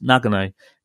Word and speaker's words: Nagano: I like the way you pronounced Nagano.